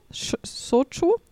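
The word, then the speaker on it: Shochu (焼酎): Und zwar werden da asiatische, riesige Hornissen, manchmal auch Wespen, ertränkt in so klarem, destillierten, muss mir mit der Aussprache helfen, Shochu? – Shochu, ja, aber es sieht einfach aus wie eine riesige Suppe.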